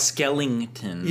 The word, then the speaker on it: Skellington: Jack Skellington.